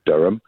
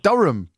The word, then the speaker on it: Durham: I think it's come up before, and I'd never noted that Durham is a ham, because you don't pronounce it that way, do you?